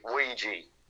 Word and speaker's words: oui: But he would later claim that it is actually a combination of the French and German words for yes. – We and ja. – Oui. – Yeah. Okay. Interesting. – O-U-I and J-A. – Interesting. – Yep. We. Oui. Yeah. So, okay. – Or as Americans say, Ouija. – Yes. – Ouija. – Get the Ouija board. – Get that Ouija board. – Oh, say it correct, like.